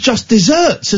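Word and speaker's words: just deserts: Can you spell it? – D. Yeah. E. – Yeah. – S E R T S. – Well, that's just desserts. – No, it's not. – Don't tell me you knew this already. – Because of what you deserve, rather than you don't deserve. – I didn't know this. I thought it was just desserts, as in Knickerbocker Glories. – Wow. – Why would it not be? It's pronounced just desserts.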